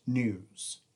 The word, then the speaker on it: NEWWS: I'm giving it the acronym NEWWS, pronounced news.